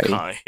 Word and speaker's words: Honkai: Honka Star Rail, I hope I pronounce it, or Honkai. – Honkai, yeah.